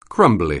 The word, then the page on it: crumbly: anglais